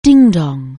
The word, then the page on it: dingdong: anglais